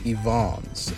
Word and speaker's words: Evans: Or rather, I should say Andre Evons, as he is quick to remind you, is the proper pronunciation of his name. That is, you know, of his name, not the group name. So his name is Evons, but the band is Evons Pyramid.